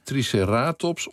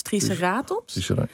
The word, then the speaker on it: triceratops: Het is triceratops of triceratops, toch? – Ja. Het mag alle twee. – De ene is Latijn meer? – Ja, de Latijnse, correct Latijnse uitspraak is klemton op de ene laatste klinker, dus triceratops.